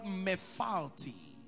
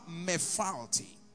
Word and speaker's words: mephalti: But you see, in the Hebrew, the word my deliverer personified is the Hebrew word mephalti. Jehovah Mephalti. I will spell that for you so you don't miss it out. M-E-P-H-A-L-T-I. M-E-P-H-A-L-T-I.